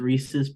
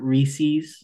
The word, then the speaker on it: Reese's: I just want to make sure everyone knows and that it's clarified that it is pronounced Reese's Puffs and not Reese's.